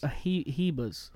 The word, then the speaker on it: Ribas: I think everybody's calling Ribas. Ribas. – Ribas. – Because, you know, Brazilian, yeah, Portuguese.